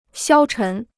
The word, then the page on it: 消沉: xiāo chén